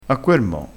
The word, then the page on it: mo: dialettale